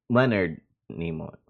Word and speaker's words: Leonard Nimoy: Leonardo Nimo, I can't fucking say his name. Spock, the guy who plays Spock. Leonard Nimoy, there we go.